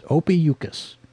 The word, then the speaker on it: Ophiuchus: It's spelled O-P-H-I-U-C-H-U-S. Ophiuchus. Ophiuchus, I guess.